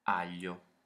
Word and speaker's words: aglio: Aglio.